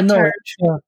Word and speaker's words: Norwich: Oh yeah, Norwich.